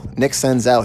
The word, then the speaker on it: Nick Senzel: Also, I have a, you know, if Yahoo, if you're listening, I'm just curious why Nick Senzel, which is how you pronounce it, Nick Senzel, he just came up with the Reds.